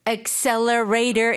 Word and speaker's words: accelerator: That's why we have the accelerator in our car. – 근데 그거 한국에서 악셀했다 이렇게 발음하시는 분들 엄청 많아요. – Oh, okay, Right. – accelerator, we say.